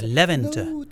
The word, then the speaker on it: Levente: Da hat er mir gesagt, dass die Worte auf der ersten Silbe betont werden und mich auch gleich bei der Aussprache seines Namens korrigiert. Nicht Levi, sondern Levi oder Levente.